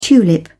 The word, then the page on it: tulip: British English